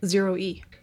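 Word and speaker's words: ZEROe: How would you pronounce that? – Zero E. – Zero E. – I guess it's Zero E because it's like zero emissions, I guess.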